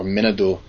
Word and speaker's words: Manitou: when you hear the word Manitou or Minadu, as it's often pronounced up north, it's something that hits you a little bit more deep than the word God or the word deity.